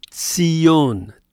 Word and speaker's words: Tzion: Zion, in Hebrew, is pronounced Tzion, Tzion.